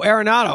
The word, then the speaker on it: Arenado: Well, you got Arnaud. How do you pronounce his name in Colorado? – Oh, Arenado. Oh my goodness.